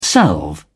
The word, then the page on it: salve: British English